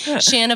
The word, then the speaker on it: Shanna: Shanna Banana. That's the one I help others pronounce my name because sometimes they want to say Shannon or Shayna or something like that.